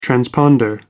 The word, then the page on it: transponder: American English